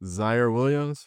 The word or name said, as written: Ziaire Williams